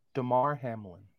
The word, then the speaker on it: Damar Hamlin: Damar Hamlin.